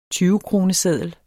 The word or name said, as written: tyvekroneseddel